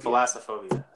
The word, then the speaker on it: thalassophobia: We, what, Thalassopia, what is, how did you say it? – Oh, yeah. What is it? Thalassophobia? Thalass. – Yeah. Yeah, it's spelled Thalassophobia. Thalassophobia. Thalassophobia. – Thalassophobia. That's what it is.